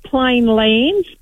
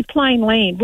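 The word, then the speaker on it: Plainland: I always call it plain land. But it's actually plain land, which doesn't sound right. – There's no S. – Yeah, there isn't an S, but we do that.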